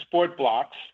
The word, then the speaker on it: Sport BLX: So we actually pronounce it sport blocks.